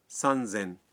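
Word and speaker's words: سن زین (さんぜん): 3000 کو کہتے ہیں سن زین اور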